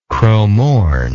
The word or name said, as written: cromorne